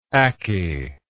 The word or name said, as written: ackey